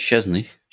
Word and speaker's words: Szczesny: It's Szczesny. – Szczesny, there we go.